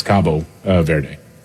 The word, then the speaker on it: Cabo Verde: Yeah, Cape Verde, or in Portuguese, it's Cabo Verde.